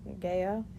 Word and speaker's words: Gaia: Like, I knew about Gaia.